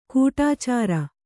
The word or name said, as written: ಕಡೆಚೋರಿ